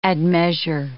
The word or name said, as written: admeasure